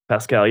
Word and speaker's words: Pascal: I think it's Pascal, Pascal, yeah.